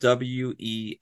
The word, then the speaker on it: Weisz: My name is spelled Weiss, like Sam, Z like zebra.